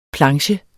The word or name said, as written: PLANCHE